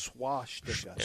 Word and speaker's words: swastika: I may have said schwa instead of swastika. Oh. It's swastika.